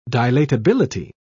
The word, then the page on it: dilatability: British English